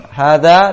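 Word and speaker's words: hadza (هٰذَا): Nah, hadza dibaca panjang, ha-nya ditulis pendek, dza ditulis panjang, dibacanya pendek, hadza.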